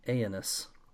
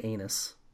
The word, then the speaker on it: Aenys: I am pronouncing this name Aenys, and you guys, I had a crisis of conscience when I tried to think about how to say this name because I didn't want to say anus.